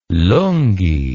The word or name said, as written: lungi